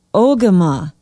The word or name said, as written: ogema